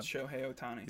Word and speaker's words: Shohei Ohtani: Shohei, which I kept saying his name wrong. I think I got Shohei Ohtani is his name. But Shohei Ohtani.